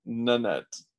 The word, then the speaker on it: Nanette: it's Annette, but with an N in the front. Nanette.